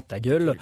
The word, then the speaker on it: Taggle: Hein, le titre du jeu d'aujourd'hui qui s'écrit T-A-G-G-L-E, mais comprenez. Prononce effectivement ta gueule et ça plante d'emblée le décor et le côté complètement décalé et déjanté de la chose.